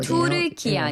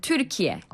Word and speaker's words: 튀르키예: 투르키 아니라 튀르키예. – 아, 튀르키예. 자, 튀르키예가 맞다고 합니다.